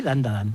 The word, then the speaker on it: Dandadan: Tenemos Dandadan, creo que lo he dicho bien, Dandadan. – Sí, Dandadan.